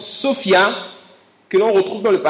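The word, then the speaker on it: Sophia (σοφία): le mot sagesse ici est un mot grec que l'on prononce Sophia. Est-ce qu'on peut dire avec moi Sophia? – Sophia.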